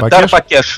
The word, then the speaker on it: Дарбакеш: У нас, э, напомню, в гостях э, руководитель школы восточных барабанов Дар Бакеш. Я правильно ударение ставлю? – Да, Дар Бакеш, Дар Ба, это удар.